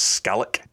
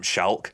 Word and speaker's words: Schalk: The correct pronunciation on his name is Skalik, but he'd been in the league for 2-3 years and everyone had just called him Schalk.